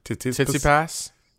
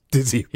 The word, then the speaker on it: Tsitsipas: Tsitsipas. – Tsitsipas.